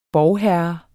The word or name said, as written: borgherre